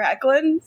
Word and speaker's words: Hoechlin: Tyler Hoechlin of Palm Springs and having a good butt fame.